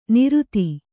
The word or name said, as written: ನಿರುತಿ